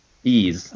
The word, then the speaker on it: Ys: Yeah, they, it's spelled YS, ease uh, to say it.